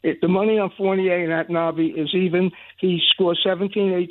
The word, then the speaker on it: Anunoby: You say his name for me, Larry, the wing on Toronto, A-N-O-N-B-O-Y. – Anunoby. – Anunoby.